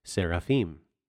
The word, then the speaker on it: seraphim: The Hebrew word seraphim is pronounced seraphim. Seraphim, seraphim. It's the exact same word.